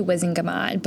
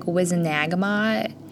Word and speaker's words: Wizengamot: I say Wizengamot, but I've also heard, like, Wizenagamot. – Wizenag. – Which, like, there's not a second A. – Yeah, but is after the G. – Yeah, I pronounce it Wizengamot.